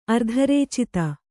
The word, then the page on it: ಅರ್ಧರೇಚಿತ: ardharēcita